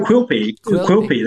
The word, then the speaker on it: Quilpie: How do you say that? Quilpie? – No, Quilpie. That's exactly right.